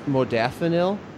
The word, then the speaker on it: modafinil: but I think it's pronounced modafinil or Provigil.